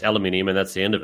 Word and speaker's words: aluminium: So copper involved in everything, aluminium, alumina, or however you say it, the same thing. – Aluminium, also, that's how you say it. How is it? There's no other way of saying it.